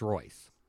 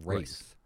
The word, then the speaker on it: Reus: It's Reus. I call it Reus. Reus.